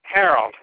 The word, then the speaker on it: Harold: Harold, H-A-R-O-L-D.